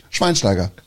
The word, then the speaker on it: Schweinsteiger: Shiwainushi Taigari. Schweinsteiger.